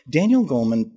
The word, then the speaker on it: Goleman: Is it Daniel Goleman who, did I get his name right? – Daniel Goleman, he really popularized it.